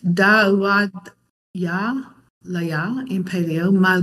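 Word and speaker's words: Da'uad (דוד): Dave pronounced it Dawid. Is that how Dave? I think that's how he's pronounced it. That is how it's usually pronounced in the modern Israeli Hebrew. And you could pronounce it David, or even Dude. I always thought that was kind of funny. In the Paleo, it's Da'uad. Da'uad.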